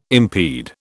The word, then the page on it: impede: British English